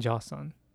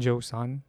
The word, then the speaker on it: Joseon: So yeah, I have high respect for Bong Hwan for respecting the body that he had while he was in Joseon. In Joseon. Okay, it's weird to say Joseon because that's how English people pronounce it. But Joseon. Joseon. In Joseon.